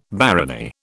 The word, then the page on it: barony: British English